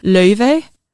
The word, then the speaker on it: Laufey: In true, like, clean Icelandic, it's pronounced Laufey.